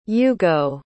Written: ugo

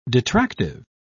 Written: detractive

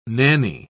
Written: nanny

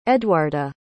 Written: eduarda

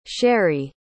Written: Sherrie

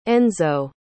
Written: enzo

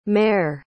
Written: maire

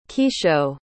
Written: Kisho

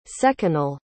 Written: seconal

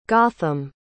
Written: gotham

